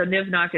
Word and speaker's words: nimhnach: So nivnach is the Irish word for poison. It's spelled nimhnach.ie.